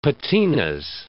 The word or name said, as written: patinas